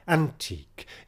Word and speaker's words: antique: Even, as so often, is pronounced with one syllable, een, and antique, as we would say, here is stressed on the first syllable, antique, in order for the line to scan.